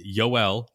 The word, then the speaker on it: Yoel: It's Yoel, not Joel. Yoel Pyamps, not Payamps.